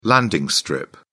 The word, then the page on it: landing strip: British English